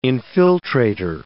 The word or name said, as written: infiltrator